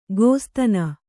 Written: ಗೋಸ್ತನಿ

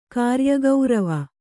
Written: ಕಾರ್ಯಗೌರವ

